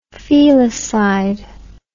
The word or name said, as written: felicide